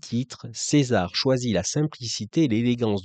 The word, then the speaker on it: Czesare: Ça s'écrit C-Z-E-S-A-R-E, mais on dit César, qui a sorti en juin son EP Alba.